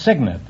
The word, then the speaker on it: cygnet: No, it's Cygnet. It's spelled C-Y-G-N-E-T, but it's pronounced Cygnet.